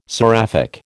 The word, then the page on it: seraphic: American English